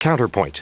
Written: counterpoint

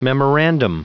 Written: memorandum

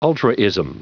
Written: ultraism

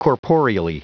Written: corporeally

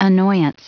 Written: annoyance

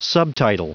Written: subtitle

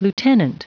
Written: lieutenant

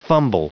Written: fumble

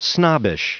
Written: snobbish

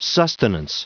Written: sustenance

